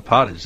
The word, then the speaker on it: pottage: As I said, apparently the first records of plum pudding dates to the early 15th century when plum potage, how do you say P-O-T-T-A-G-E? – Yeah, that's right. I would say pottage.